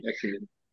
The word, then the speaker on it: ecumenism: ecumenism. I'm not saying that right. – Ecumenism. That's right.